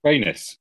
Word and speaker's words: Rhaenys: Yeah, we're obviously talking about Eve Best, but how did you pronounce her name? – Princess Rhaenys? Wait, what? – Rhaenys. – I just want to say is clearly using the Essos pronunciation, the proper Valyrian one, rather than this kind of Westerosi bastardization.